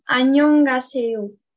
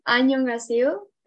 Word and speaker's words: 안녕하세요: So, how do you pronounce the formal hello? Do you know how to pronounce the formal hello? – So this is 안녕하세요. – One more time. – This is 안녕하세요, and this is 안녕.